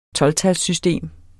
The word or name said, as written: tolvtalssystem